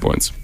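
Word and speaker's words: BØRNS: Do we know, is that how it's pronounced? It has that little, like... – Yeah, it is. – The no. – Mm-hmm. – Like the... – Yeah. It's pronounced Borns.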